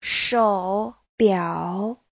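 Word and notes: shoubiao: shou3biao3

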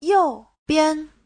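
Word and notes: youbian: you4bian5

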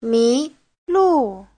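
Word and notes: 迷路: mi2lu4

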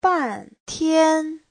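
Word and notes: 半天: ban4tian1

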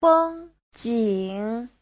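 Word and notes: fengjing: feng1jing3

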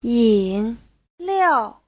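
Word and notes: yinliao: yin3liao4

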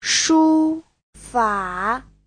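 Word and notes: shufa: shu1fa3

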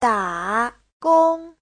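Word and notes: dagong: da3gong1

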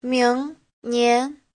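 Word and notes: 明年: ming2nian2

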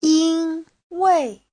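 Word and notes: yinwei: yin1wei4

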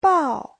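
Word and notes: 报: bao4

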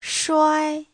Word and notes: shuai: shuai1

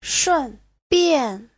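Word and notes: shunbian: shun4bian4

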